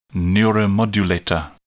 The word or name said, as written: neuromodulator